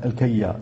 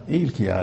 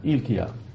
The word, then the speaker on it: إلكيا: وبعضهم ينطق به خطأ الكيا لا إلكيا هذا يعني كما هي اللفظة الفارسية إلكيا بكسر الهمزة وسكون اللام إلكيا.